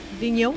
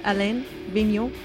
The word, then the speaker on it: Vigneault: Vigneault. How do you pronounce his first name? – Alain Vigneault.